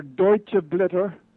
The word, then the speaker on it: Deutsche Blätter: Okay, it's called German Leaves or Deutsche Blätter für ein europäisches Deutschland gegen ein deutsches Europa.